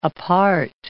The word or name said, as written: apart